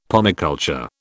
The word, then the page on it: pomiculture: British English